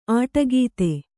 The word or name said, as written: ಅಡಕೊತ್ತು